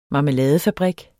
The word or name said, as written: marmeladefabrik